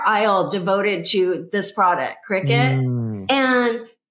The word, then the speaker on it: Cricut: But cricket is spelled C-R-I-C-U-T. And for years, I was pronouncing that Cricut. And when I was talking to her, I'm like, oh my gosh, I love Cricut. And she's like, oh yeah, no, it's actually pronounced cricket.